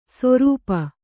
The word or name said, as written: ಸೊರೂಪ